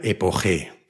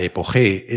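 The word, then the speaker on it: epoché: se pronuncia epoge, pero se escribe epoche. Según Baywell, el truco detrás de la epoge es que te hace sentir mejor porque te libera de la necesidad de encontrar la respuesta definitiva a cualquier cosa.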